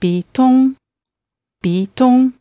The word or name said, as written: bitong